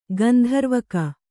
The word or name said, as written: ಗಂಧರ್ವಕ